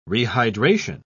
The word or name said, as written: rehydration